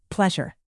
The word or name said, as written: pleasure